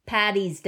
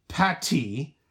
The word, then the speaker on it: Paddy: Like St. Paddy's Day. – Oh, not like St. Patty, like a hamburger patty.